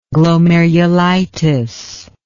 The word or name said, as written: glomerulitis